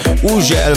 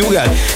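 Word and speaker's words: Hugel: Így van, az úriember, akinek minden országban másképp ejtik a nevét, van, ahol Uzsel, van, ahol Hügel, van, ahol Ugel, meg mindenféle kép, de nálunk úgy mondjuk, hogy Hügel, egy Szaltó és egy Jamaican, bam-bam érkezik, csavarjátok fel a hangerőt.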